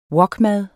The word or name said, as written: wokmad